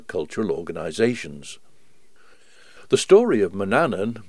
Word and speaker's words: Manannan: And the old words from about 1500 include the name as both Manannan and Manannan. The usual pronunciation these days is Manannan.